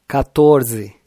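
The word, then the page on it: 14: Brazilian Portuguese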